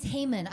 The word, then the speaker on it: Haman: It's Haman. I looked it up. Haman, H A Y M A N is how you pronounce it.